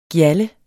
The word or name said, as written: gjalde